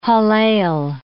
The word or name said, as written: Hallel